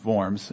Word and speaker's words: Worms: at what's known as the Diet of Worms, or pronounced Worms.